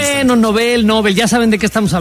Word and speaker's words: Nobel: Porque creo que es Nobel, ¿no? La pronunciación correcta. – Nobel, en efecto.